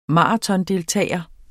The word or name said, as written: maratondeltager